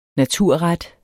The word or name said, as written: naturret